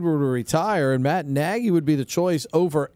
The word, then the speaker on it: Nagy: Rhymes with Shaggy. And a former NFL head coach. I can't give you any more. – Oh, Nagy.